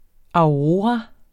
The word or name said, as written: aurora